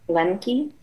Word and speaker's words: Lembke: Lembke.